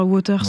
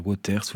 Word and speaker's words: Wauters: Votre nom de famille s'écrit W-A-U-T-E-R-S et souvent en France on dit Wauters, mais je vous ai demandé et on dit bien Wauters. – Oui, on peut dire Wauters ou Wauters, c'est selon.